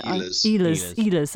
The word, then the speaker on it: Ehlers: Line A, Scheifele, how do you say the guy's name? Ellers. I never know. – Ehlers, that's it, yeah.